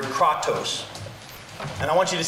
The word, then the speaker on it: kratos: E-N-K-R-A-T-E-I-A. E-N-K-R-A-T-E-I-A. The root word of this Greek word is the Greek word kratos, or K-R-A-T-O-S. K-R-A-T-O-S, kratos, which means power or control.